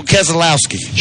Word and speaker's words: Keselowski: How about Brad Keselowski? – Brad Keselowski. – Did I say it right? – Yeah, well, Keselowski.